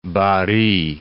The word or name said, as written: Barry